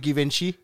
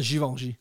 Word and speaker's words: Givenchy: Ja, jeg kaldte det Givenchy. – Ja, det hedder Givenchy.